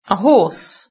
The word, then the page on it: a horse: British English